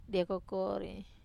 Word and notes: dia kokori: wamesa

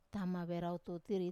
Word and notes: tama verau tutiri: wamesa

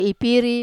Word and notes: piri: wamesa

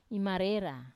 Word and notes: imarera: wamesa

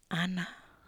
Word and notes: ana ii: Wamesa

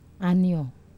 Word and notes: anio: Wamesa

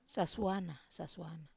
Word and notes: sasuana: wamesa

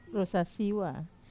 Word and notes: rusasiwa: wamesa

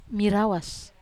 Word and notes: marawas: wamesa

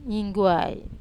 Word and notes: ningguai: wamesa

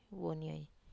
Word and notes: wonioi: wamesa

